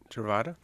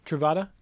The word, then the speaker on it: Truvada: Truvada. – Truvada.